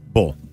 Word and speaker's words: Bowl: Bull. Like in bull. – Wait, spell it. – Bull. – B-O-W-L.